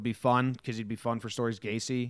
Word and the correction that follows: Gase: is that how you say it? – Gase. Adam Gase.